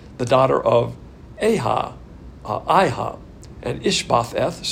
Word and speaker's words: Ish-bosheth: Now he's the son of Saul, and his name's difficult to pronounce, but it's Ish-bosheth.